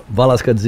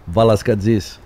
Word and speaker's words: Velasquez: Did I say that right? – Correct. Pretty close. – How do you say it exactly right? – Velasquez. It's a big, big, big Greek name.